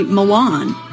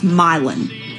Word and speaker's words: Milan: You'd think this would be Milan, like, you know, the city in Italy. No, it's Milan.